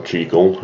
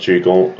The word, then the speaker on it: Jekyll: Moving on to some of the other members, we have Dr. Jekyll or Jekyll, however you want to pronounce it. It's also pronounced Jekyll, and Mr. Hyde.